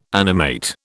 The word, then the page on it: animate: British English